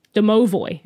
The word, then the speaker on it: Domovoi: Domovoi is how you pronounce it.